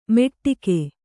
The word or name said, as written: ಮೆಟ್ಟಿಕೆ